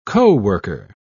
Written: coworker

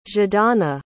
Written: zhadana

